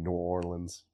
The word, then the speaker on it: New Orleans: I don't always say that wrong. Should be New Orleans.